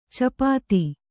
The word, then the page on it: ಚಪಾತಿ: capāti; Kannada